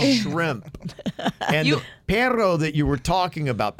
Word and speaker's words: perro: Perro with two R's is a dog. – Pero is how you say it, yeah. Pero or perro. – Pero. – Double R. – Yeah, if they really roll the R's, you got a dog.